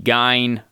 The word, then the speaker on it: Gein: In German, it would be Gein.